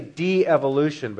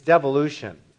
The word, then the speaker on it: devolution: You want to say de-evolution, but that would be D-E apostrophe evolution. It's devolution. You think, well, you're making up words.